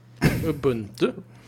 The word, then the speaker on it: Ubuntu: So that you know how to pronounce, but not Ubuntu. – Ubuntu. – And right, Ubuntu.